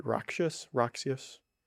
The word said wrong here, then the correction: ruckus: Really, ruckus.